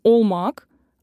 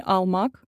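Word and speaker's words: olmak: Поэтому если в слове О, то нужно произносить как О и не изменять на А. Так, например, школа — окул, а не акул. Глагол «быть» становится «олмак», а не «алмак», а Москва — «Москва».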